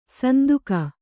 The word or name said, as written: ಸಂದೇಗ